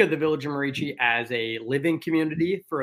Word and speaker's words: Merici: Is it Mercy Village or the Village of Mercy? – The Village of Merici. So Merici.